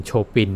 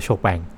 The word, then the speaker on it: Chopin: โชปินนะ C H O P I N เออแต่อ่านว่าโชแปง